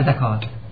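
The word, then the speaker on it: زكاة: نقطة اخرى احسن لا ليس من القرآن، اصلا العرب الجاهلي يكتب زکوت و يقرأ زکات، فهو معرب من كلمة زکوت و كتابتا زکوت الان هذه الكتاب زکوت لكن تتلفظ زکات. هذه نکته.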